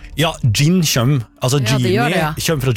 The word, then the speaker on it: jinn: Gin tonic, ja. Nei, ja, det skrives med jod. J-I-N-N. Gin. Men også det uttales gin, for på engelsk skrives det med D først. Gin.